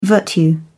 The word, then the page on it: virtue: British English